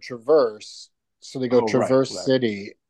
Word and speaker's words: Traverse: So how do you say Traverse City, Michigan when you say it? – Traverse, just like you did, Traverse City.